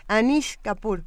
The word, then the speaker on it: Anish Kapoor: La aplicación se baja nada más con escribir Anish Kapoor, que se escribe como se oye, bueno, con doble O.